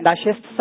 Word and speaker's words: نشست: ناشست صحیح اور نشست غلط ہے. – یعنی نون پر فتح زبر.